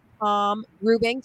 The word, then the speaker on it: Rubing: The second being Tom Rubing.